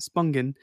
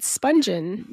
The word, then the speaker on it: Spungen: So, to start the story of Sid Vicious and Nancy Spungen, apparently, not Spungen, Spungen, I was saying it wrong.